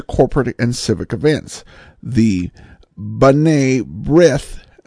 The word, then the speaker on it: B'nai B'rith: I finally figured out he was trying to say, and I'm going to butcher it, please, apologies, Benai Berith, the Jewish charitable organization. The pronunciation, as I heard it on New York airwaves growing up, was B'nai B'rith. B'nai B'rith.